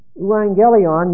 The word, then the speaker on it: euangelion: E-U-A-N, or we pronounce this in A-G-G-E-L-I-O-N. Euangelion means good news.